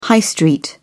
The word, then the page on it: high street: British English